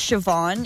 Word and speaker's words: Siobhan: Like, my sister's name is Siobhan. It's Gaelic. – Okay. – So it's spelled S-I-O-B-H-A-N.